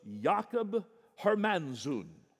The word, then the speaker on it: Jakob Hermanszoon: His name was Jakob Hermanszoon. That's roughly as close as I can get to the actual Dutch pronunciation, which, when brought over into Latin, which I think everybody and their dog spoke in the 1600s, came over as Jacobus Arminius.